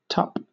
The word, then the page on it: tup: British English